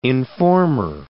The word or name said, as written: informer